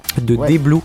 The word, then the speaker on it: DBLOU: D-Blue, c'est comme ça que ça se prononce pour des interviews canadiennes et québécoises.